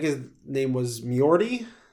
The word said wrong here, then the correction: Njord: Does that sound right? – Njord? – That was Njord. – Yeah. N-J-O-R-D. – Oh, okay. – So that's how it's spelled.